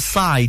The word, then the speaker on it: Psy: But I can tell you that Psy does rhyme with pie.